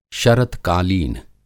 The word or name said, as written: शरतकालीन